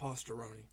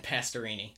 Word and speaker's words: Pastorini: Not Pastorini. – Pastorini.